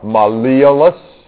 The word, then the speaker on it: malleolus: And who can pronounce this one right here? Yeah, that's a malleolus.